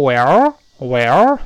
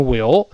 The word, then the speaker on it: Well: 那么同学在群里打卡的时候呢，有人把它读成 Will，Will，有人把它读成 Well，Well，这个都不对。那第一个，它字母一发 i，所以要张开一点点。然后呢，后面说，哦，明白了，那个哦，不要卷舌，不要动 Well，更不要读成 Will，都不对啊。